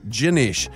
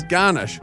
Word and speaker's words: Ganesh: His actual name was Ganesh, but pronounced Ginesh. However, someone mispronounced it as Garnish, like sprinkles on a cake.